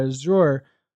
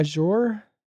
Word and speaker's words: Azure: Azure. How do you say that? Azure? – Azure.